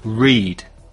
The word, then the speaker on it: read: To read. Escucha y repite. Read, read, read. The spelling is the same. Se escribe las tres formas igual, pero no se pronuncia igual.